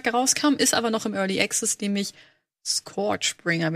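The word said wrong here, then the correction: ScourgeBringer: wenn ich das richtig ausspreche. – Mhm. – Und das starten. Ich mach mal Sound kurz noch an. – Sperriger Titel. Guck mal. – Ja, Scourge. Scourge. – Scourge. – Scourge. Bringer. das ist ein Roguelike.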